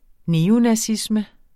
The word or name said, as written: neonazisme